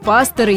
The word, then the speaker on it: пастор: Но сейчас просторечная форма с ударением на последний слог в слове «пастор» не установилась. А это значит, что будут пасторы из-за границы расспросить пасторов, задать вопрос пасторам и общаться с пасторами.